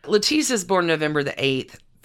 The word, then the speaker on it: Lettice: Lettice, spelled like lettuce.